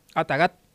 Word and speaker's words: ataxat: En Com, por ejemplo, es Ataxat, ¿así digo bien? – Atagat. – Atagat, la X como G. – Se escucha como G en la fonética.